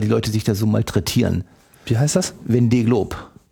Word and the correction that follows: Vendée: ausgesprochen habe, das ist Französisch. Mit V. Von, ja, Vendée. Vendée Globe.